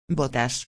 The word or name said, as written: botas